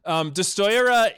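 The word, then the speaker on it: Destoroyah: Beautiful pronunciation. Destroya. No, you were perfect.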